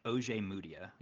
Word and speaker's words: Ojemudia: It's like Ojemudia. You pronounce it in that kind of chunked segments. Ojemudia.